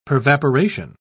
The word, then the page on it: pervaporation: British English